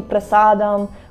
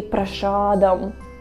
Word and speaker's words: prasadam: Instead of saying prasadam, we say prashadam, prashadam, because that's how Americans say.